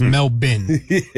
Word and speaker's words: Melbourne: Is it Melbourne or you say Melbourne? How do you say it? – Yeah. Well, I thought it was Melbourne when we started today, but it's Melbourne, okay. And that is courtesy of Noah Eagle. – Yeah, so Melbourne. The Australian pronunciation, is Melbourne.